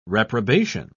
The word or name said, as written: reprobation